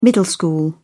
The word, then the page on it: middle school: British English